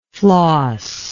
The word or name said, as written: flosser